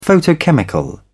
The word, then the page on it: photochemical: British English